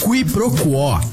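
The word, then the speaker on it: quiproquó: Qui pro quo. Q-U-I-P-R-O-Q-U-O com acento agudo.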